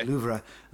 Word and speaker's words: Louvre: Louvre. But anyway, I say the Louvre, so I'm sorry.